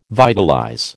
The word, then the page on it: vitalize: American English